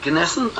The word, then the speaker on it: Gnessen: Karen Pine and Simone Gnessen, and you do pronounce the G, co-authors of Sheconomics.